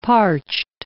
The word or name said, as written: parched